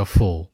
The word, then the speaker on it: full: 大家注意一下啊，有一个词的发音就是 foolish 的词根叫 fool，就是有点圆口的。而还有一个词吃饱了叫 full，不怎么张口的。它们俩区别在于呢，发音很像。